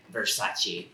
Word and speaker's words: Versace: Like, Donatella, the most annoying mispronounced word, and she's like, Versace.